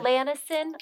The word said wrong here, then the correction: lanolin: Lanolin. That's what it is. Lanolin. Why are you laughing? That's what it is. – I do not trust your pronunciation of things. – Rude. Lanolin. There's no S.